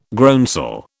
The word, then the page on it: groundsel: British English